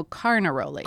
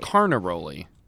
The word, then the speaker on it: Carnaroli: Canaroli. No, Carnaroli. – Carnaroli. You're faking me out. – Sorry. – Canaroli, Carnaroli. – And we're not rolling our R's.